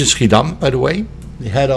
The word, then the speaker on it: Schiedam: Schiedam. – Schiedam.